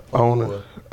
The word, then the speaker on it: Apuah: Kwame, how do you say your second name? – Apuah.